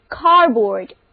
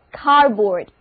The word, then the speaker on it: cupboard: 这个单词 C-U-P-B-O-A-R-D Cardboard，注意它的发音，因为辅音 P 和 B 连在了一起，前面那个辅音就失去爆破，在这里就不发音了。另外组合 O-A-R 在这里因为重音在首音节上，发音轻化，读作整个单词就读作 Cardboard。跟我练习一遍 Cardboard。